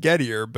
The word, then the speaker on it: Gettier: It's, I mean, I think he pronounces it Gettier, but you know, Gettier. Just for those people who might be thinking that we're mispronouncing a French word, we know that it's Gettier, but it's actually.